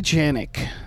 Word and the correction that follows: Janiak: Am I saying that right? – Janiak, maybe? – Janiak.